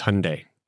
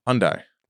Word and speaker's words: Hyundai: It's Hyundai. – Hyundai. – Rhymes with Sunday. – What did I say? – Hyundai. – You said Hyundai. – Hyundai. – So it's Hyundai rhymes with Sunday.